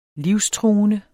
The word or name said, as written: livstruende